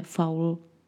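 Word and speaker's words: Fowl: Mimochodem, kdo třeba zná z dětství nebo třeba i ze současnosti sérii dětských knih o mladém géniovi Artemisi Faulovi, který se píše Faul jakože drůbež, tak tam je využita ta stejná hříčka, protože to jeho jméno je v přepisu sice drůbež, ale vysloveno evokuje Artemis Strašný, což kdo ty knihy četl, tak ví, že eh, to k té postavě velmi sedí.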